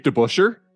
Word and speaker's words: DeBusschere: And he played in the Dave DeBusschere.